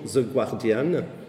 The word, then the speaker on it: The Guardian: Grande leçon de journalisme, parce que même les journalistes très euh, qualifiés en France disent toujours The Guardian, et ça se prononce The Guardian, voilà.